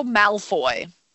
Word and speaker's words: Malfoy: Draco Malfoy.